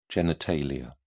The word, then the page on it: genitalia: British English